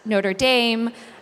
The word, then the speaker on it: Notre Dame: So you have actually a lot of researchers at Duke Nicholas School of the Environment, at University of Toronto, at Notre Dame. That's how you pronounce it when you're talking about the American University.